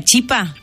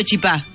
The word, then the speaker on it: chipa: Bueno, ¿podemos dejar en claro entonces la pronunciación correcta es la chipa? – Sí. – Hay que decir así, está mal dicho chipá.